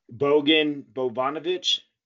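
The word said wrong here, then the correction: Bogdan Bogdanović: Bogdan Bogdanovich.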